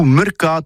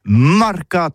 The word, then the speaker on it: marcat: Eh bien, dis ça à tous, mais le mercat, ou le mercat de l'Uni, ou le mercat des rebelles, mais en gascogne toulousain, c'est prononcé sur les marcat, avec un A, marquette.